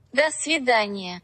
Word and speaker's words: До свидания: Dosividania. Tschüss. – До свидания.